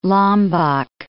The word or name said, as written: Lombok